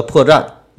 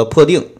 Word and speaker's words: 破绽: 嗯，但是如果有明显的这个读音错误，比如说，呃，我露出了破绽，那我读成了我露出了破腚，那么这样的话，我还是要甘愿受罚的。